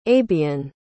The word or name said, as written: abyan